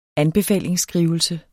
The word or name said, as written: ANBEFALINGSBREV